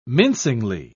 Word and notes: mincingly: British English

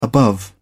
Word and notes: above: British English

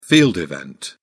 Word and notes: field event: British English

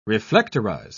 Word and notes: reflectorize: British English

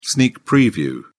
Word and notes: sneak preview: British English

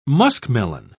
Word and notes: muskmelon: British English

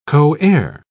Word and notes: coheir: British English

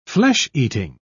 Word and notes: flesh-eating: British English

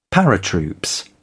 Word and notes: paratroops: British English

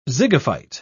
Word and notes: zygophyte: British English

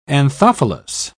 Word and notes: anthophilous: British English

